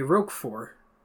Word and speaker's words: Roquefort: But it's French. They don't pronounce the T at the end. It's just got to be Roquefort. Nah, whatever. Roquefort. And they just don't pronounce it. You're saying it with a hard T. It's like Roquefort, not Roquefort.